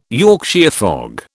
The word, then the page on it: Yorkshire fog: British English